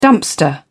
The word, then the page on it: dumpster: British English